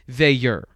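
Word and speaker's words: Veilleux: I like what I see from Christian And I believe you said that the proper way to say it is Vayur.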